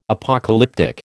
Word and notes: Apocalyptic: American English

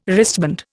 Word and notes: wristband: Indian

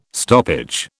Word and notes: stoppage: British English

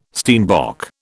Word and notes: steenbok: British English

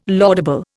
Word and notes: laudable: Indian

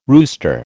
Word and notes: rooster: American English

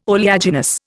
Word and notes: oleaginous: Indian

